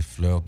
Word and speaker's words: fleur: which is Les Fleurs du Mal. – All right. – And so it's fleur, like rhymes with burr, which is butter.